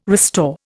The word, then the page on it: restore: Indian